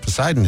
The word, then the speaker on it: Poseidon: Poseidon is correct. – A not silent P that I feel like should be silent.